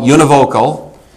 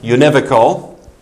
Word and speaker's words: univocal: First one is called univocal, or sometimes pronounced univocal.